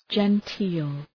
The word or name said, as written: genteel